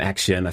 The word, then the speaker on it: Accion: Um, but yeah, so on that list, you've got the likes of Greycroft Partners, 500 uh, Startups, um, Action, I think that's how you say it, ACCION.